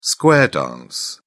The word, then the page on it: square dance: British English